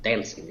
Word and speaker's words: Dancen: It's called Dancing. It's spelled Dancen.